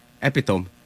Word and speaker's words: epitome: Is it epitome or epitome? – Epitome. – Okay, yeah. I've been pronouncing it. My entire life. – No, I'm kidding. – It's epitome is right.